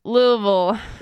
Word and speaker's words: Louisville: And remember, it's pronounced Louisville. Say it like you're drunk on bourbon.